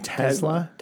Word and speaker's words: Tesla: Are we supposed to pronounce it with like a Z of the S? – Yeah, I think so. – It's supposed to be Tesla. – Tesla. Yeah. – Not Tesla. – No. Tesla.